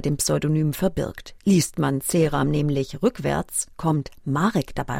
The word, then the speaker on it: Ceram: C.W. Ceram oder spricht man ihn Englisch aus? Ceram.